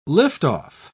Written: liftoff

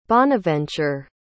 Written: Bonaventure

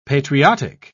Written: patriotic

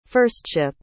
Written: firstship

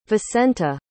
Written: vicenta

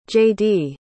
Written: jaydee

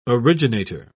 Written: originator

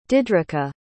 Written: didrika

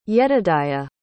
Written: yedidiah

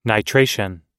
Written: nitration

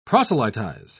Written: proselytize

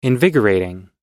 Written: invigorating

